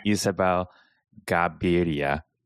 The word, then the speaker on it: Isabel Gaviria: So first we have Isabel Gaviria.